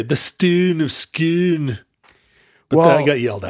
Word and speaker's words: Scone: The Stone of Scone. – How did we say? We also said scone? – Stone of Scone, because that makes sense, because it rhymes. – Stone of Scone? – And we say S-C-O-N-E is a scone.